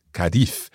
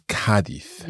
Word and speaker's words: Cádiz: And I have to also stress that the stress of Cádiz, the pronunciation of Cádiz, I think native English speakers very often think it's Cádiz, yeah, but it's not. It's Cádiz.